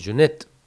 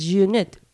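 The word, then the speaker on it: Jeunet: De Junet. – No sé pronunciar ya. – No Jeanette, porque si no va a estar hablando de literatura. – No, Jeanette. – De Junet. – Claro, Jeanette, el otro es Junet. – Junet y Cabo.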